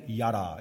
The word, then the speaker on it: yadah: And one of them is yada. It's spelled Y-A-D-A-H.